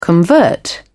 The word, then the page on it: convert: British English